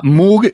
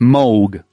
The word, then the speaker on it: Moog: Bueno, se llama Moog y está mal pronunciado. Moog, porque Moog. Es Moog. Teclados. – ¿Es Moog el instrumento? – Claro, el teclado se pronuncia Moog.